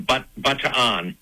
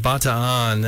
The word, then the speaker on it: Bataan: Yes, I noticed you said Bataan. That's how it's pronounced there. I say Bataan. You know, it's just a matter of what people are familiar with.